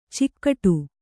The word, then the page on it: ಚೌಗಡ: caugaḍa